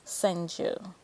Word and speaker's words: Senju: Senjin. Is that how you pronounce his last name? Senjin? I'm probably butchering it. Senju.